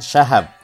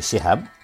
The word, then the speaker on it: Shihab: Aba Abdurrahman memilih Shihab, meskipun di Indonesia lebih populer Shahab. Aba bilang, kita pilih pengucapannya yang benar, walaupun tidak populer, daripada memilih yang populer tapi tidak tepat, kata Quraisy.